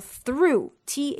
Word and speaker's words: through: 别忘了，还有另外一个字叫做 through，t h r o u g h，竟然还能够发一个 u 的音哦。